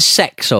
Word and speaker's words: CeX: And, they pronounce it sex over here, but that's not how it's spelled. CEX, you know, the computer exchange.